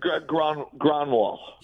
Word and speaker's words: Grönwall: Eric Grönwall.